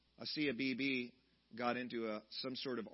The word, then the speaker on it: Asia Bibi: Sometimes I pronounced her name Asia Bibi because it's spelled just A-S-I-A. But Asiya Bibi, praise God, just this last week was able to finally leave the country of Pakistan.